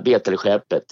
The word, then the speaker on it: Betelskeppet: Är det Betelskeppet eller Bette? Eller vad säger man? – Ja, Betelskeppet.